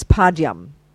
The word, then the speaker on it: Padyam: That's padyam. Padyam. P-A-D-Y-A-M. Water for washing the feet. Padyam. The next one is pupe, flowers, P-U-P-A-Y if you like, pupe, flowers.